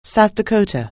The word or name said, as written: South Dakota